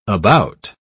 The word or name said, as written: about